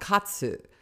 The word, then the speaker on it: katsu: It's called katsu, right? Katsu. But that's the short version. So some people call the sandoichi a sando, right? Because that's just hip to say sando, and the katsu sando.